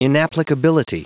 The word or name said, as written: inapplicability